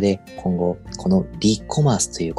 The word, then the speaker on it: REコマース: 皆さん、 e コマースという言葉は聞いたことがあると思うんですが、では、リコマース、 re コマースと書いて、リコマース